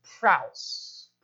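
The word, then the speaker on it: praus (πραΰς): The Greek term here is the term praus. Praus. If you spell it in English, it would be P-R-A-U-S.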